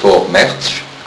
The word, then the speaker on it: Vormärz: vivent sur le souvenir de ce qu'on appelle le Vormärz, ça se prononce comme ça,